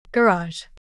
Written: garage

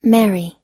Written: marry

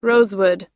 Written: rosewood

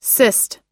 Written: cyst

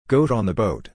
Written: goat on the boat

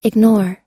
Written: ignore

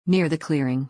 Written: near the clearing

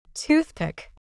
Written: toothpick

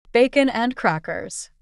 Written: bacon and crackers

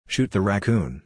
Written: shoot the raccoon

